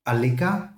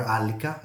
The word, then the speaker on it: Alika: Um, it's um, Alika, or Alika, I believe is the correct pronunciation.